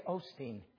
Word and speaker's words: Osteen: Last name rhymes with Keen. Okay, Osteen.